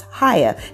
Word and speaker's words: Chaya: oh, no, it's not Chaya. It's Chaya.